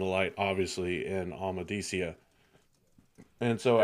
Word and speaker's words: Amadicia: So they keep moving, and eventually they find themselves in Amadicia. Sue me, because I probably said it wrong, but whatever. – No, you said it correctly.